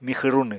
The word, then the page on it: mixw runw: Apatani